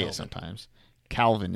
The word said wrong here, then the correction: Kelvin: is so much easier to say. – No, Kelvin. – Calvin is easier to say, though. – Like Fahrenheit, Celsius, Kelvin.